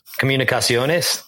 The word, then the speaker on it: Comunicaciones: From Spain, and one with, and I'm probably going to butcher this, Comunicaciones. – Comunicaciones.